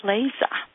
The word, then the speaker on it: Schleese: It's S-C-H-L-E-E-S-E, pronounced Schleese. S-C-H-L-E-E-S-E. So am I hired? – You're hired, yeah, absolutely.